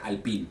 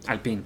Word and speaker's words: Alpine: Bueno, y ahora, ahora estuve o sea, siendo que, que Alpine es una, una, escudería francesa, entonces se debe pronunciar Alpine. Alpine, con, acento la I al final.